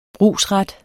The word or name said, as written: brugsret